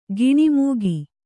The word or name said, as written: ಗುಣಮುಖ